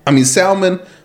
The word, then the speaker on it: salmon: Why is salmon versus salmonella? Which letter is silent? How come the L is silent in salmon and salmon? – Right. – But it's not silent in salmonella.